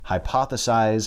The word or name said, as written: hypothesize